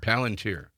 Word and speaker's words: Palantir: How do you pronounce it? Palantir. – Palantir, yeah.